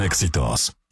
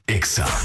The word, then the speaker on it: Exa: No se dicen éxitos. Se dice Exa.